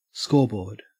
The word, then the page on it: scoreboard: Australian English